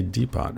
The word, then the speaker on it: Depot: Like, do you pronounce that last T or not? Ergo er, ergonomic Depot. I guess in American you say Depot, right?